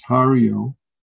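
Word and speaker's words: Haryō: This Baling, or in Japanese is pronounced Hariyo, he's famous for three questions.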